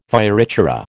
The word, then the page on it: fioritura: American English